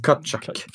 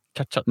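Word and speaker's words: Tkachuk: Så ja, Kakchak lär det väl uttalas. Mm, Katchak. Katchak. Med ett K.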